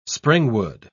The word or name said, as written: springwood